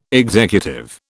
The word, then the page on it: executive: British English